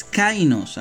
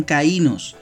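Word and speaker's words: kainos (καινός): Y la palabra es kainos, algunos lo pronuncian caínos, en griego,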